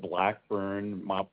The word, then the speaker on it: Blackburn: Yeah, it's Joe Blackburn, probably Joseph on your screen. – Okay, and that last name is spelled B-L-A-C-K-B-U-R-N? – Yes.